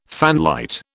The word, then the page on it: fanlight: British English